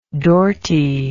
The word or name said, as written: dorty